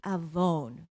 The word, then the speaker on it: avon: The Hebrew word for iniquity is avon. That's what the slide there is. It kind of rhymes with iPhone.